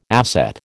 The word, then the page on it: asset: American English